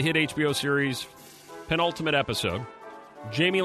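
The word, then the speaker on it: Jaime: Did Jamie, is it pronounced Jamie? – Jamie Lannister? – Yeah, Jamie Lannister.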